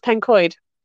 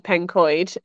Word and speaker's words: Pencoed: And it's a very simple one, but everybody who lives there pronounces it wrong. It drives me a little bit crazy. And that's Pencoed. – Oh, right, Pencoed. – Oh God, when people call it Pencoed, I just want to scream.